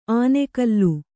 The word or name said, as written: ಆನೆಗಲ್ಲು